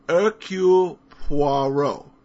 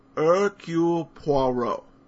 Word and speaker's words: Hercule Poirot: I might suggest the phonetic pronunciation. Here goes. Hercule Poirot. Hercule Poirot. I think the Poirot sounds most believable.